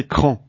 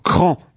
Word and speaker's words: Craon: Il faut prononcer Cran, d'accord. Alors, bonne route et gros bisous pour Cran.